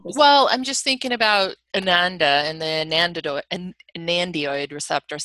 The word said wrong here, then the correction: anandamide: I may have mispronounced it. – Anandamide is the anandamide, excuse me.